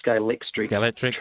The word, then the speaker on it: Scalextric: Scalextric track.